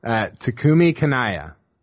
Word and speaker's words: Takumi Kanaya: Takumi Kanaya.